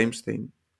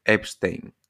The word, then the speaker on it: Epstein: Eu estive onte lendo sobre o caso de Epstein. Non o pronunciei ben. Epstein.